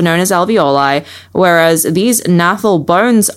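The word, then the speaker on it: gnathal: There's a silent G at the start of nathal.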